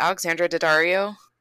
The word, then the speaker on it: Alexandra Daddario: And then I watched, I think this is on Netflix, but We Summon the Darkness with, is it Alexandra Daddario? – Yes, that's how you say her name.